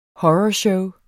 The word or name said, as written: horror